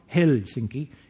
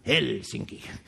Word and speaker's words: Helsinki: So it's not Helsinki. It's Helsinki. The first vowel has to be pronounced.